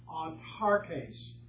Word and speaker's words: autarkes: It's pronounced autarkes. And it's A-U-T-A-R-K-E-S. A-U-T-A-R-K-E-S.